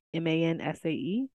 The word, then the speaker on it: Mansae: I have, I think that's how you say it. Mansay or Monsay, Mansae. – Yes.